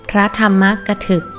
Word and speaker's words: พระธรรมกถึก: อ่านว่าพระธรรมกถึก